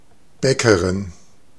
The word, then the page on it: boulanger: /bu.lɑ̃.ʒe/